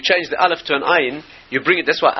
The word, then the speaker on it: ayin: Ayin is pronounced. Anybody who speaks Hebrew correctly, you can hear the ayin. It's one of the guttural letters. It's not a silent letter.